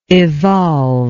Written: evolver

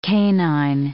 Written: canine

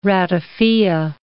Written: ratafia

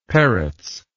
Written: Perutz

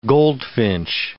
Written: goldfinch

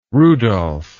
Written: rudolph

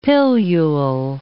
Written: pilule

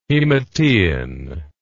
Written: hematein

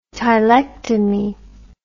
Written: tylectomy

